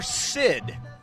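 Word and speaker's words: Seid: His name is Spencer Sid. However, it is spelled S-E-I-D. I'm going to spell that phonetically just so I make sure I get it right.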